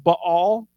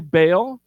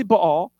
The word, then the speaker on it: Baal: We don't typically like the way that Baal is pronounced in Hebrew. It's actually pronounced Baal, but that kind of sounds weird. Doesn't flow. So I'm going to say Baal, but I just want to educate you that it's actually Baal.